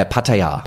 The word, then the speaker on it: Pattaya: Yeah, Pattaya.